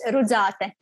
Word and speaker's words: Rudzate: And it's Rudzate.